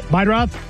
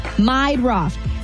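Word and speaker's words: Meidroth: Chase Murdaugh? Murdaugh? The White Sox guy? – Meidroff. – How do you say that? – Meidroff.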